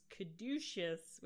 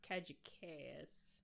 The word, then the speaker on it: caduceus: My word that I always mispronounce was caduceus, which I always pronounce cajicaeus.